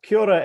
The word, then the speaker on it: kia ora: Kia ora, Evan.